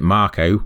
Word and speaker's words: Mako: There's other things like Mako or Marco, the new game, Final Fantasy VII Remake, calls it Marco when I've been calling it Mako. – I call it Mako.